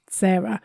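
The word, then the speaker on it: Sara: My name doesn't have an H on it, but it's still pronounced Sarah.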